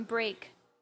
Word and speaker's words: Brak: The first album, Break. – B-R-A-K. – Yes, well, spelled B-R-A-K. Yeah. It's like the phonetic spelling of break.